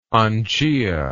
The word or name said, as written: angiya